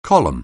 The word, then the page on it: column: anglais